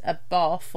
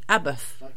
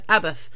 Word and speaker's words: Abbath: It was a bath or a bath, and it's actually Abbath, like Sabbath, Take Sabbath, take the S off. Take the S off. Yeah, so that's Abbath.